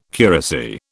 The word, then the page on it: curacy: British English